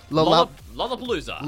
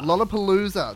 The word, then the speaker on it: Lollapalooza: Lollapalooza. – Lollapalooza.